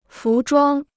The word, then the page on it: 服装: fú zhuāng